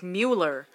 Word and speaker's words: Mueller: Is it Mueller or Mueller? It's spelled like Mueller. But there's an E in it. – But it's pronounced Mueller.